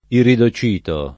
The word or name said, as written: iridocito